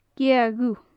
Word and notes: gèadh dhubh: gaelic

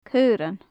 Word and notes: caorann: gaelic

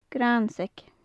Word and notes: grainnseag: gaelic

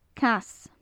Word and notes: cas: gaelic

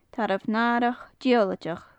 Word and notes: tarbh nathrach diollaideach: gaelic

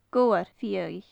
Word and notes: gobhar fhiadhaich: gaelic

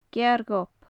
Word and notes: cearr ghobh: gaelic

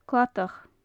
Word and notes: cladach: gaelic

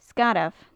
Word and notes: sgarbh: gaelic

